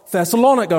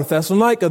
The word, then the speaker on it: Thessalonica: And so it was for Thessalonica or Thessalonica, depending what way you wish to pronounce it.